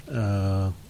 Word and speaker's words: Gal: Well, according to my extensive research on IMDb, she pronounces her first name like Dahl. – With a G. – With a G. Gal. And her last name rhymes with float. So Gal Gadot.